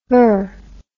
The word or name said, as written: ver